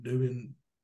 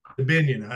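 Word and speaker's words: Dubinion: Is it Dubion? – DeBinion. – DeBinion. I knew I was butchering his name.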